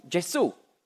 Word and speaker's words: Gesù: Darum sagt man auf Italienisch eben nicht Jesus, sondern Gesù. Und auf Französisch Jésus, auf Englisch Jesus, aber das ist alles das Gleiche.